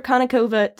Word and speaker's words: Konnikova: Well, welcome so much, Dr. Konnikova.